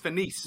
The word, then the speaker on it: Venise: It's Venise. – Relais de Venise. – Venise? – Yeah. – Oh, nobody knows how to pronounce it.